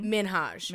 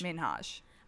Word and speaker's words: Minhaj: Did I say that correctly? – No. So it's actually Hasan. – Hasan. – Minhaj. – Minhaj. Hasan Minhaj.